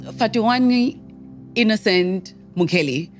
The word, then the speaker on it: Fhatuwani Innocent Mukheli: Fhatuwani Innocent Mukheli. Yes. That's how I pronounce it.